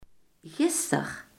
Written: gisteren

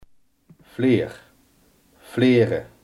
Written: vlierbessenstruik

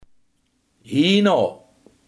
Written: hierna

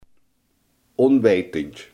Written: onwetend

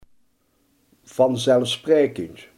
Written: vanzelfsprekend

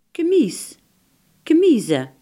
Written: douanebeambte

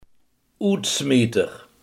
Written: uitsmijter

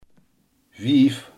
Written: vijf

